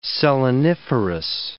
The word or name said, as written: seleniferous